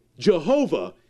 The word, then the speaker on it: Jehovah: Jehovah is simply a European pronunciation of that Hebrew tetragrammaton. We stuck in some vowels from His other names in Hebrew, A from Adonai and E from Elohim, and we end up with Jehovah.